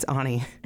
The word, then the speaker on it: Ani: How many Ani DeFranco fans does it take to screw in a light bulb? – How many? – It's Ani.